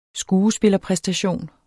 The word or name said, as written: skuespillerpræstation